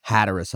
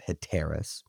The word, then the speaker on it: Hatteras: When they took a break at Hatteras Island, apparently I was mispronouncing Hatteras Inlet in the previous episode, before the review episode, and a listener corrected me on that, which I appreciate. It's Hatteras Island or Hatteras Inlet. I was pronouncing it Hatteras.